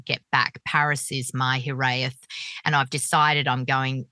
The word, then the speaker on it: hiraeth: And then you mention a Welsh word, and I don't know the pronunciation. – Hiraeth.